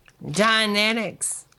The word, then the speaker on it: Dianetics: Dianetics.